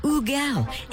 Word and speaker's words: HUGEL: His name is spelled H-U-G-E-L. I would automatically go to call him Hugel, which he's fine with. Technically, the correct pronunciation is Ugel.